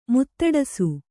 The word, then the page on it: ಮಾತಾಡಿಸು: mātāḍisu